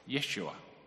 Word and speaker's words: Yeshua: Or more likely, it would have been pronounced something like Yeshua.